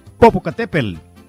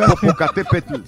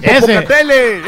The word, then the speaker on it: Popocatépetl: El Popocatépetl. – Sí, sí, lo, sí pronuncié bien. Popocatépetl, sí.